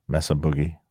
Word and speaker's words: Mesa Boogie: Mesa Boogie.